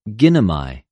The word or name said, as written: γινομαι